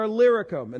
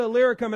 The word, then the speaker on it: Illyricum: The passage even points out that he's been from Jerusalem all the way to Illyricum. And that's how you say it. I looked it up because I had no idea. From Jerusalem all the way to Illyricum.